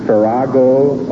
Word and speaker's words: farrago: The correct spelling is F-A-N-F-A-R-O-N. – She was third last year, wasn't she? – Right. – This may be pronounced either Farago or Farago or Farago. – Farago is spelled F-A-R-R-A-G-O. – Farago. – Farago. F-A-R-R-A-G-O.